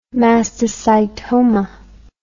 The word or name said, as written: mastocytoma